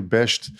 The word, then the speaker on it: Besht: Am I saying that correctly, Besht? – Yes.